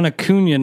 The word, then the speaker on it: Acuña: You got Ron Acuña number one. I guess it's Acuña. I was mispronouncing it forever, but it makes sense with the accent.